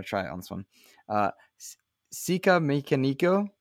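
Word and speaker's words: Sikamikanico: I say Sika Mechanico. A lot of people just call me Sick. But yeah, in the Red Hot Chili Peppers song, they do indeed pronounce it as Sika Mechanico.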